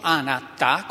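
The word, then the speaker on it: anattā: E cioè il concetto di anatta, è una piccola parola che si scrive così come la pronuncio, anatta con la A finale lunga.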